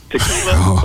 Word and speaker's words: Tequila: My name is actually Tequila, like the drink.